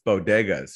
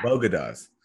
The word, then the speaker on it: bodegas: But what has not gotten enough attention is that she also mispronounced bodegas. She called them bogadas.